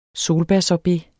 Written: solbærsorbet

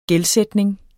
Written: gældsætning

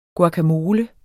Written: guacamole

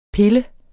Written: pille

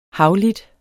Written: havlit

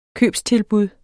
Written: købstilbud

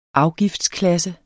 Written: afgiftsklasse

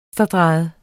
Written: venstredrejet

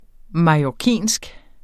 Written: mallorcinsk

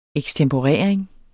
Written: ekstemporering

